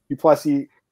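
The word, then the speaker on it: du Plessis: Duplessis, the S is silent, yes.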